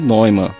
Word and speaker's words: Neumann: Voltando ao bug report dele, que então a pronúncia é Neumann.